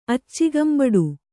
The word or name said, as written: ಅಚ್ಚಿಗಂಬಡು